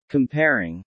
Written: comparing

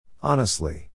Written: honestly

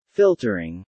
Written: Filtering

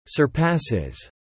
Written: surpasses

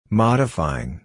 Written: modifying

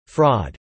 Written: fraud

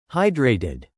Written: hydrated

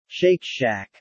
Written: shake shack

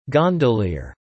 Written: gondolier

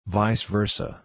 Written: vice versa

